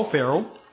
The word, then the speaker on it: Ferrell: Again, my name is Paul Farrell. Last name is spelled F-E-R-R-E-L-L.